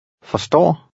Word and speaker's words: forstår: In number 19, you'll hear the short sound of this vowel when it's followed by two consonants. The RS combination usually makes the R lightly pronounced. Read 19. – Forstår.